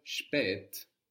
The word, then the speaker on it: spät: In German, when you get an S and another consonant starting a syllable, you will get this sh sound appearing. For example, the word spät, spelled S-P-A with two little dots,